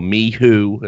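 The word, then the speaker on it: MICHU: They're bringing in Mishu, or Mihu, as apparently it's pronounced.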